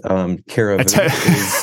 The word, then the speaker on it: Cariveau: Yeah, excellent attempt. Cariveau is how I pronounce it.